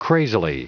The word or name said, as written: crazily